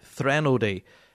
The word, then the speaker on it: Threnody: Let me see, is it Threnody? Threnody. Threnody. Yeah, the brand new album from Mark Saul, Threnody.